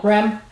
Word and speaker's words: REM: REM.